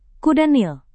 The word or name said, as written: kuda nil